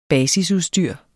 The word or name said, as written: basisudstyr